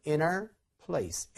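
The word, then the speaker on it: in our place: 그 다음에, 그러니까 in our place도 어떻게 발음돼요? inner place, inner place, inner place 이렇게 됩니다. 그럼 in her place는 어떻게 돼요? 누가 her 이렇게 읽어주는 사람은 없어요. 그러니까 이것도 inner place, inner place 이렇게 되는 거죠.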